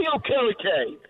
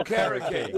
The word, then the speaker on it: Okereke: Uh, Bobby Okarika, uh, Riki, or how do you say his uh, name? Bobby Okereke. – Oh, Okereke.